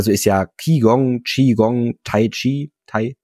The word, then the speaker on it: Qigong: Tatsächlich, Qigong wird eigentlich immer dieses Ch, Ch ausgesprochen. Qigong.